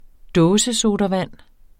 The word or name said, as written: dåsesodavand